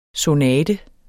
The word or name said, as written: sonate